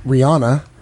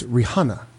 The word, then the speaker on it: Rihanna: No, it's not Rihanna. It's Rihanna. Everybody calls her Rihanna.